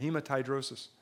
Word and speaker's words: hematidrosis: Is how you say it, hematidrosis.